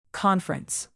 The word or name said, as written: conference